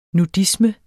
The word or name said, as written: nudisme